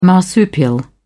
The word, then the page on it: marsupial: British English